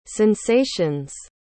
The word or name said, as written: sensation's